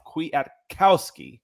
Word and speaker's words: Kwiatkowski: The Atlanta Falcons signed a new inside linebacker, Nick Kwiatkowski. I looked it up and it says put the emphasis on the cow. So cow is in pal. Kwiatkowski, about 245 pounds.